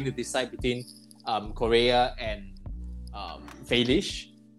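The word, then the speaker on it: Félix: Felish, according to the right way to pronounce his surname. But João Felix has been... – Oh, Felish.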